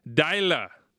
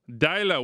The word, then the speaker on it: Deila: His name, we think, is pronounced Ronnie Deila. Deila. We asked an actual Norwegian.